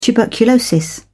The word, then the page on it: tuberculosis: British English